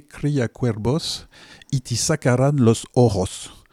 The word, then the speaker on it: Cría Cuervos: Je ne sais pas si je le dis bien. Le V, on dit bien B en espagnol. – Cría Cuervos, oui. – Cría Cuervos.